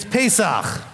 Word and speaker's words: Pesach: It's Pesach.